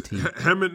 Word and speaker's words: Hemant: And then I don't know who Hemant, H-E-M-A-N-T. – Oh, yeah. It's Hemant Mehta.